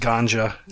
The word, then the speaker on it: Gonxha: I'm still amazed that her uh, her middle name is pronounced Ganja, and I just wanted yeah, to.